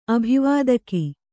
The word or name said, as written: ಅಭಿವಾದಕಿ